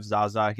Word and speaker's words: XaXa: So today, super excited to have XaXa here.